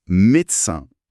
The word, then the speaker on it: médecin: Vous êtes médecin. Réponse, oui, je suis médecin. Écoutez bien la prononciation. Mé-de-cin. En trois syllabes.